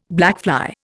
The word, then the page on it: blackfly: Indian